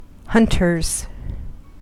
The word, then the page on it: hunters: American English